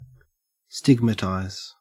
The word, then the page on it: stigmatize: Australian English